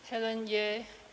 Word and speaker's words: Helen Yeh: Helen Yeh, H-E-L-E-N-Y-E-H.